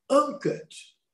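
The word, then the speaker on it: enquête: porque ela preferia dizer, como no original francês, enquete. Como é que fala enquete? Enquete não existe. Fala pesquisa, então. Sondagem. Agora, se quer falar, fala certo. É uncut, não é enquete.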